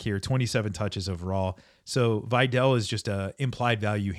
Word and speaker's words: Vidal: Okay, we've all been saying it wrong, apparently. It is not Vidal as in Sassoon. It is Vidal.